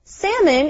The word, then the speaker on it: salmon: Salmon is a kind of fish. It is spelled differently than it sounds. So when you say the word, make sure to say salmon. There's no L sound.